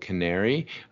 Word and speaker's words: Qnary: I'm the founder and chairman of a company called Canary. We spell it horribly with a Q, so Q-N-A-R-Y.